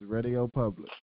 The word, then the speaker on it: Radio Public: Did I still say it wrong? – It's Radio Public. – See, you keep adding the re on it. I don't know why you keep adding the re part on it.